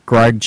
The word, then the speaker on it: Greg: So check out Facebook, Greg, G-R-E-G, last name is spelled D-U-D-Z-I-N-S-K-I.